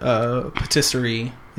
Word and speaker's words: patisserie: Yes, this is a Disney-owned patisserie. Is that the right way to say patisserie? – Patisserie.